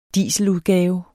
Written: DIESELUDGAVE

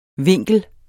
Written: vinkel

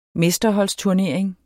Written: mesterholdsturnering

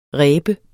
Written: reb

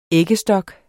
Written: æggestok

